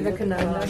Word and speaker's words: Vivekananda: How do you say it anyway? – I have no idea. – Vivekananda. – Vivekananda. – Oh, I was close. I got the V right.